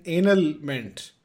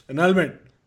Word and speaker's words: annulment: And she's reading and she's like, what is an anal meant? And Sam is like, no, that's not how you say it. Annulment.